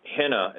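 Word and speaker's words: Henna (ἵνα): It's a Greek word, a conjunction. Henna is how it's pronounced, and a henna clause is a clause of purpose or result.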